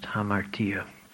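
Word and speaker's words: Hamartia (ἁμαρτία): In English letters, that would be spelled Hamartia. It's pronounced Hamartia.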